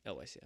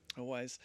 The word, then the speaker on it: Elwes: I saw a post the other day, Cary Elwes. – Yeah. – Is that how you say his name? – Elwes, yeah. – Elwes.